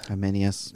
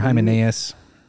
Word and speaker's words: Hymenaeus: How do you say this guy's name? H. – Hymenaeus. – Hymenaeus or Hymenaeus? – Hymenaeus. – Yeah, it depends.